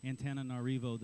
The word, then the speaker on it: Antananarivo: And there are very few, there are actually very few cities or nations more poor than Antananarivo, the capital of Madagascar.